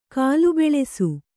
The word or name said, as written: ಗಲಬಲಿಸು